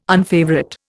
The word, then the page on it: unfavourite: Indian